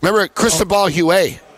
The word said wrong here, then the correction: Huet: Huet. – Huet.